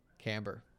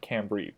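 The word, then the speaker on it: chambray: Camber. – I've heard cambry, but I'm inclined to say camber. – Right, camber, cambray, cambry. I say camber.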